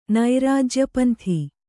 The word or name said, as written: ನೈರಾಜ್ಯಪಂಥಿ